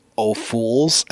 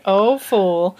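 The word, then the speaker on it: Ophüls: Because the first time I ever heard his name, it was pronounced Ophuls, and I was like, no. – Ophul. – That's Ophul, not right.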